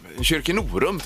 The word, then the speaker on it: Kyrkenorum: Vill skicka en hälsning till mina goa och härliga kollegor på Kyrkenorums förskola i Stenungsund. – Ja, Kyrkenorum tror jag man brukar säga. Okej, det var Kyrkenorum. – betoningen där ja.